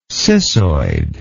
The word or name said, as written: cissoid